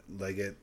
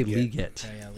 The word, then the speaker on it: Lockett: On your bench, I mean, you got Tyler Lockett with that touchdown. Leggett. – They say Leggett.